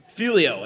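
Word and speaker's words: Figlio: No, but our name is Filio, and it's actually spelled with a G.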